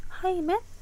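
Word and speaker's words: hymen: 하이맨? 음. – 하이맨. 어, 네, HY인데 하이맨 하이맨인데 네. 하이맨이에요 발음이 아, 하이맨이에요 근데 진짜.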